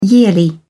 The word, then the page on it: yearly: English; British English